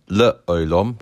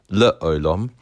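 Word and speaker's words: le olam (לְעוֹלָם): Shva na is sounded, for example, the word le olam, where the shva is at the very beginning of the word under the lamed, le olam,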